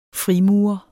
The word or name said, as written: frimurer